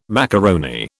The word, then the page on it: macaroni: British English